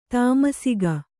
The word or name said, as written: ತಾಮಸಿಕ